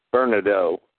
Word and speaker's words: Vernadeau: Well, you go to my channel there, which is Richard Bernardo channel. I'll spell that last name for you because it's a French name and it's kind of hard to spell if you don't know how. It's VERNADEAU.